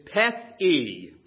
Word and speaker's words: Pethe: The first word that were going to be considered is Pethe, P-E-T-H, and then E-E. That's the way it's pronounced.